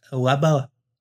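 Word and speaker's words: Leppalúði: But it's Lebaluthi. But it's not spelled like that at all.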